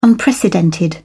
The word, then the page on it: unprecedented: British English